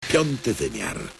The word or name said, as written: Piąty wymiar